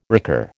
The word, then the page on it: recur: American English